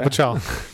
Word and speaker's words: Pachal: There was a piece on Mashable today by Pete, I'm going to mispronounce his last name, Pachal, talking about how that's how he says it, Pachal Pachal.